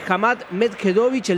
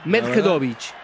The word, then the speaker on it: Hamad Medjedovic: Y juega Joao Fonseca ante Hamad Medjedovic, el serbio, y está ganando 2-1 el brasileño. – Qué bien te salió esa pronunciación. – Sí, ¿no? Medjedovic.